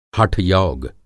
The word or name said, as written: हठ-योग